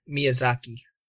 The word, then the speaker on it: Miyazaki: Watch the trailer for The Red Turtle, Studio Ghibli's first release post Hayao Mizuzaki. Studio Ghibli proves they are still the masters of animation even without the esteemed Miyazaki. Sorry, I butchered that the first time.